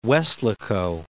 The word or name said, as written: Weslaco